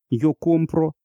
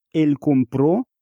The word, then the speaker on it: compró: Так, например, разница между «я покупаю» – «yo compro» и «он купил» – «él compró» – только в ударении.